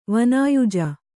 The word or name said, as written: ವಾನಾಯುಜ